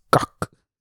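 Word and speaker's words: kak: Oh, by the way, if you're an American listening from America, here in South Africa, especially in the Afrikaner community, poop is called gak, all right? And that's how you pronounce it, gak.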